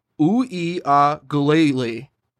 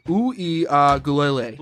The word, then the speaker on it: Uiagalelei: Okay, so his name is DJ Uiagalelei. Say that with me now. DJ. – DJ. – DJ Uiagalelei. – Uiagalelei.